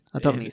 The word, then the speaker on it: Attorney: Eh, de este lado del charco casi no he visto fanáticos de la saga Ace Toner y mira, Attorney, Attorney se pronuncia.